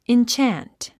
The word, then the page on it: enchant: intʃǽnt; インチャント